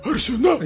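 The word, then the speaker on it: Arsenault: Арсена! Я был на встрече с ней, я, я, я знаю, как фамилия произносится.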